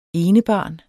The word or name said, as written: enebarn